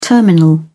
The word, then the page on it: terminal: British English